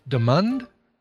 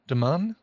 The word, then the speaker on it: Demond: caller, did you say your name was uh, Demond? Demond? – Demond.